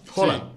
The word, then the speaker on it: Haaland: Yo escuché hoy a un amigo dominicano que estudió en Noruega y dijo que ese apellido se pronuncia Haaland. – Haaland.